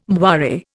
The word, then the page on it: moire: Indian